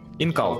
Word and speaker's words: Incal: об этом, но э, по французским правилам ударением на последний слог. Инкал.